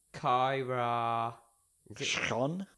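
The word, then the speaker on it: Kyra Schon: Kyra. – Sean? – Yeah. – It's spelt K-Y-R-A space S-C-H-O-N. – Kyra Sean.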